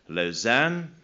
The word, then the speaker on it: Lausanne: which I now know is pronounced Lausanne.